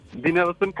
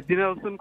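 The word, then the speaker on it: Denelson: Dinelson Cox. – What's it? Hello? – Yeah, Dinelson Cox.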